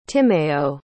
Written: Timéo

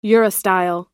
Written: urostyle

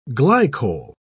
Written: glycol

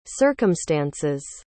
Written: circumstances

